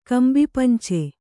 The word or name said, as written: ಕಂಬಿಪಂಚೆ